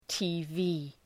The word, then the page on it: TV: ,ti:’vi: